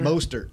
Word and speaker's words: Mostert: What did you say his name? Monster? – Mostert.